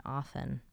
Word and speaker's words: often: I think I just realized for the first time in our relationship that you pronounce the T in often. – Often. I say that? – You just did. – I mean, I just did on purpose just now. – Yeah, it sounds like you, I mean, I think often. The listeners will be able to go back and hear it, and I think I'll prove right. – Often. Often. Yeah, what do you say, often? – Yes, it's a silent T.